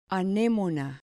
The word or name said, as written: anemona